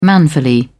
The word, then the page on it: manfully: British English